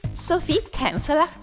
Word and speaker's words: Kinsella: Invece io mi fido di te ciecamente, tu lo pronunci? – Sophie Kinsella. – Kinsella. – È inglese, Gioia. – Ah, ok, bene, io lo italianizzavo.